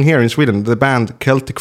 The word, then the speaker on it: Celtic: So here's the reason why I said Celtic, because there's a football team that plays in Scotland in the Scottish Premier League called Celtic, and that's how they pronounce their own name.